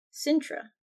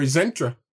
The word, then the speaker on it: Sintra: And this is in Sintra. – Or Zintra. – Zintrea. – Yeah. You gotta add a little... – Or one... It's a little elven accent. – It's been pronounced both ways.